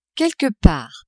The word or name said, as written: quelque part